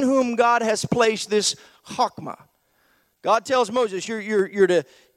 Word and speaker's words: chokmah: it's pronounced in Hebrew chokmah. I know it kind of looks like chokmah, but it's pronounced chokmah, and what it essentially means, it's first found in our focus verse in Exodus 28:3, where we just read.